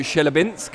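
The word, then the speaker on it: Chelyabinsk: It's the first time I've seen you guys since the big trip out to Shelyabinsk. And Yarde's... – You said it right.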